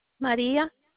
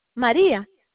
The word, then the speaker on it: Maria: Maria. – Will you spell it for me? – Maria. M-A-R-I-A. – And your last name? – Exposito. E-X-P-O-S-I-T-O.